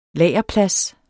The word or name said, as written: lagerplads